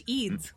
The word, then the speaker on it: Eads: George Yads? Yads? – Oh, George Eads. – Eads. Wow, that's how you say it? Eads? – I assume.